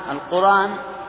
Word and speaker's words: القرآن: لمن نبذ القرآن، القرآن بدون همز وهي القرآن، لكن تنطق هكذا من أجل سياق البيت الشعري.